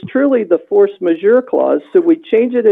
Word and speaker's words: force majeure: And so I know when we were talking about the force majeure clause, I called it the force de jure clause several times last meeting.